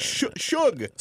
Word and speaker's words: Suge: S-U-G. – S-U-G, but it's short for sugar. It's Shug.